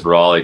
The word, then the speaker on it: Raleigh: Rayleigh? Is that how you say his name? – Raleigh, perhaps? – Maybe. – Yeah, there it is. Raleigh.